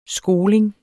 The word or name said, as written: skoling